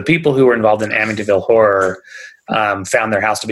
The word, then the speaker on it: Amityville: So were they the ones that wrote like Amityville? How do you say it? – Amityville.